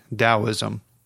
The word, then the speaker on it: Taoism: and it's spelled Taoism, but it's pronounced with a D, Taoism.